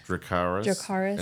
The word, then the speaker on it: Dracarys: I don't want him like to fire to Dracarys. What is it? How do you say it in Game of Thrones? – Dracarys.